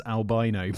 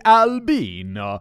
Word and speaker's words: Albino: Please resist the ungodly temptation to pronounce his name as albino. Yeah. As an English speaker would, in Italian, his name sounds more like albino.